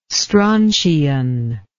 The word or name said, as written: strontian